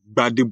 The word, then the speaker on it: Gbade: So I would like to call you Honorable Joshua Gbade Wale.